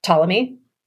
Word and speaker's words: Ptolemy: And so I'm sure that everybody knows this ancient scientist philosopher, Ptolemy, right? With the PTO, the silent P.